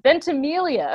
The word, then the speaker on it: Ventimiglia: And I'm going to butcher his last name, probably Milo Ventimiglia. – Ventimiglia.